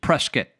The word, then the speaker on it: Prescott: Some people down there says Prescott.